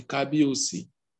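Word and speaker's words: Kabiyesi: Now, of everything that is contained within that kingdom, Yoruba used to say it like this, say Kabiosi.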